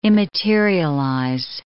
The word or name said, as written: immaterialize